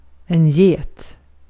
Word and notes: get: /jeːt/

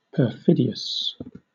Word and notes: perfidious: /pəˈfɪdi.əs/; /pɚˈfɪdi.əs/

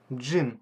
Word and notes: gin: /dʒɪn/

